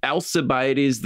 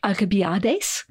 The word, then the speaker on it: Alcibiades: So in Greek, Alcibiades, you might have pronounced it something more like Alcibiades, because the letter that we see as C, the letter C as in cookie or, I don't know, circle or something, in English, we pronounce it as a soft C, Alcibiades.